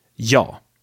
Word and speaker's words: jag: It begins with the Swedish word for I, which is jag. It is spelled J-A-G, but the G is 99.99% of the times probably going to be silent.